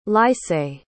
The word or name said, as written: lycée